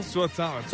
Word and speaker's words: Suotamo: Suotamo? – Suotamo.